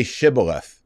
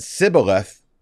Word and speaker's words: shibboleth: And if they couldn't pronounce it right, they said sibboleth instead of shibboleth, they knew what was up, and they killed him.